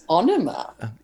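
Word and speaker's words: Ænima: So, Anima? – I think that's Onima. – That sounds, it probably is not Enema.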